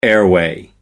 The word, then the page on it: airway: American English